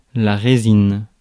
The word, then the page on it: résine: /ʁe.zin/; France